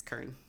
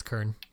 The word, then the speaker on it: cairn: It's Kern. – You were right. It's Kern.